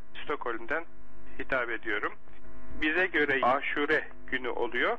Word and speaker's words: Aşure: Bunun telaffuzu, doğru telaffuzu böyle. A harfi ve U harfi uzun. Aşure. Aşura diye, hatta bizim E diye okuduğumuz Aşura diye Araplarda uzun oluyor. Elif-i Memdude ile Aşura günü. Biz Aşure diyoruz kısaca.